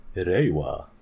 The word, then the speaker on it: Reiwa: Because, you know, recently the Heisei era in Japan ended, and now we're in the Reiwa. Reiwa? Is that how you pronounce it? – Reiwa. – Yes.